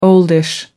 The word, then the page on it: oldish: American English